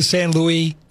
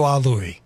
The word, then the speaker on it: San Luis: Don't do San Luis, don't do Ah Louie. – Correct, absolutely. You hear people, and apparently the original generation was Ah Louie, and the new generation said, hey, let's correct that pronunciation.